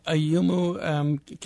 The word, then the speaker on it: Ayumu: Well, in fact, talking about a memory task, you write about a chimpanzee, Ayumi. Am I pronouncing? – Ayumo, I believe, yeah. – This chimpanzee who looks like has a photographic memory. Mm-hmm. What can Ayumo do? – So Ayumu can look at a computer screen on which 9 numbers appear and tap them in the right order.